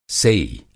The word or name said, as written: सेई